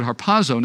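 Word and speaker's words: harpazo (ἁρπάζω): It's not, unless you know Koine Greek, you wouldn't know how to say it, but that's the word harpazo.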